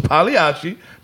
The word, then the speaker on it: Pagliacci: Pagliacci, yes, and the G is silent, so it's Pagliacci.